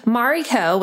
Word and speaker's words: Mariko: Mariko, which is my full name, is spelled M A R I K O.